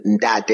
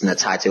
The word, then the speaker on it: Ndate: And my name, Ndate, is how it's pronounced in South Africa. But to make it a little easier, it's Ndate over here.